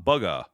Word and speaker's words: Bugha: I'm pretty sure, let's see, yeah, B-U-G-H-A, Bugha, Bugga. That's his name, Kyle Geiserdorf.